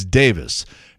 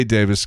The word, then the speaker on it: Davies: And by the way, apparently we've been mispronouncing his name all these years because it's spelled D-A-V-I-E-S and everybody says Ray Davies of the Kinks. Well, it turns out it's Davis and it's the British pronunciation of D-A-V-I-E-S. So I say Davis because that's how he says it.